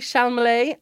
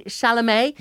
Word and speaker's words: Chalamet: My favourite skits, if you want to look them up, are Timothy Chalamet and our favourite... Was that well pronounced? – No. – Nope. Timothy Chalamet. – Nope. – Timothy... – Oh, wait, Timothy Chalamet. Yeah. Second time was right.